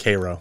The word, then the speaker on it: Cairo: I think they pronounce it Cairo. Cairo.